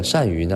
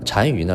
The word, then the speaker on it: 单于: 匈奴的这个单于呢，单于呢叫头曼，单于这个单是呃孤单的单啊，多音字是匈奴首领的意思。